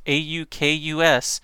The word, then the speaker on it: AUKUS: The pact is spelled Alpha Uniform Kilo Uniform Sierra. It's AUKUS. The AUKUS pact is how I'm going to say it.